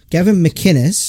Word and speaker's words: Gavin McInnes: Gavin McInnes.